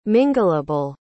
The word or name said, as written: mingleable